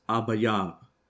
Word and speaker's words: Abib: It's pronounced Abayab.